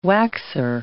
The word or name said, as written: waxer